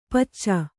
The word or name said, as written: ಪಚ್ಚ